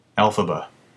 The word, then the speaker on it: Elphaba: Elphaba.